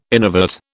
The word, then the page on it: innervate: British English